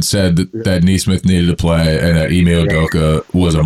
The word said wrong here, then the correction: Ime Udoka: So how do you pronounce the coach's name? I don't want to butcher it. – Ime Udoka.